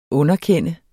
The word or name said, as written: UNDERKEND